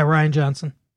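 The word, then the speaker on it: Rian Johnson: How did you pronounce him? Ryan Johnson. – Yeah, Ryan Johnson. – The spelling throws me off.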